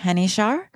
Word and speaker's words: Henichar: Photography by Marie Henichar. She is French. That is how you say it.